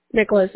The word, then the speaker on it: Nicholas: Nicholas, N-I-C-H-O-L-A-S.